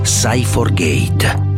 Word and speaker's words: Cyforgate: Si scrive CY4Gate, Cyforgate, la Cyber Tranquility, creata in Italia, scelta nel mondo, gruppo elettronica.